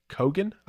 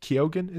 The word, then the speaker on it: Keoghan: And it stars Colin Farrell, uh, Brendan Gleeson, and also Barry Kogan, I think Keoghan is how he pronounces his name.